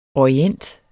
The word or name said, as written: orient